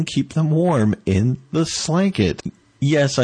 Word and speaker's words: Slanket: It's called a Slanket. It's spelled like blanket, but with an S instead of a B.